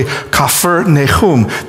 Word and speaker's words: Capernaum: As a matter of fact, the village of Capernaum, when you pronounce it in Hebrew or Aramaic, it's actually Kafir Nahum, the village of Nahum.